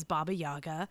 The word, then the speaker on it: Baba Yaga: If you look up any pictures of Baba Yaga, and her name is spelled in that way, the way you say it, which is Baba Yaga, she is fucking flying around.